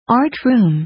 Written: art room

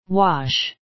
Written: wash

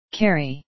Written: carry

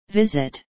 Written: visit

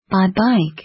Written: by bike

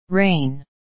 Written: rain

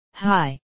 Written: hi

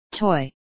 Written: toy

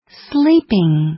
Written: sleeping

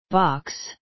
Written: box

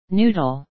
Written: noodles

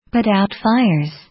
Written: put out fires

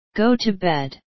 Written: go to bed

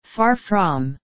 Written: far from

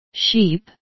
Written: sheep

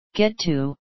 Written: get to

